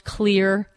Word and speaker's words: Xlear: Clear is what it's called. It's spelled X-L-E-A-R, pronounced Clear.